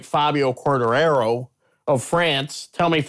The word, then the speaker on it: Quartararo: You are very, very much, that is a common pronunciation. Quartararo, I think, is closer to his home French language.